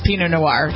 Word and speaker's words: Pinot Noir: Pinot Noir. Is that how you pronounce that? Pinot Noir? – Cabernet Sauvignon and Pinot Noir, sure.